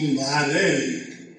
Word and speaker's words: Marell: And I shortened Mazzarello to Marell. M-A-R-E-L-L. I changed it, and I'm sorry I did, because I'm still living with the mistake, because I still have to spell it. Because even though I changed it to Marell, you know what you had to put up with, right, Dave? Marl.